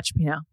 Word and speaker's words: cioppino: but cioppino, is that how you say it? – Yeah, cioppino. Yeah.